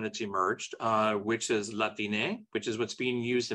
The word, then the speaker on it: Latine: But if you put an E there, it's Latine.